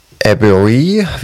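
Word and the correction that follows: Edebiri: Wie wird sie ausgesprochen? – Ayo Edebri? – Edebri oder so? Edebri, ja, genau.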